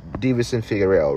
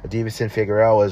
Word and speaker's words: Deiveson Figueiredo: I mean, the amazing fights that I was able to not the last weekend's fights, but the ones Figueroa, wait, am I saying his name right? Brandon Moreno, Deiveson Figueiredo, right? The third fight that they watched, they're 1-1-1, I believe now. Deiveson Figueiredo is once again the UFC flyweight champion.